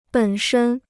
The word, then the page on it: 本身: běn shēn